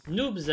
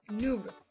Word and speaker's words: Noob: Noobs avec une note de 7,5 sur 10. – Noobs. – Noob. – Oui, arrête de prononcer le S.